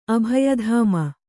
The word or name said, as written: ಅಭಯಧಾಮ